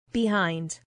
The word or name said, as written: behind